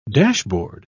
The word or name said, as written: dashboard